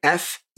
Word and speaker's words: Ferriss: Tim Ferriss, and I believe I'm pronouncing that right, Ferriss.